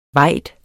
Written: vajd

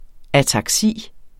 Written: ataksi

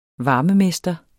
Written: varmemester

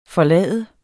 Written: FORLADES

